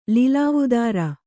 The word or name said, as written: ಲಿಲಾವುದಾರ